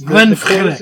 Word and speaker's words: Glenfiddich: How do you say Glenfiddich again? – Like that. You said it right. – Yes. Glenfiddich. The closing CH is pronounced as a K, but it's also glottal.